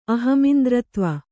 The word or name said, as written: ಅಹಮಿಂದ್ರತ್ವ